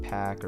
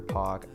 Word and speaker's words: Paak: So for me, what I've been listening to, so I'm getting into Anderson .Paak or Paak, I believe it's pronounced both ways,